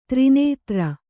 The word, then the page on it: ತ್ರಿನೇತ್ರ: tri nētra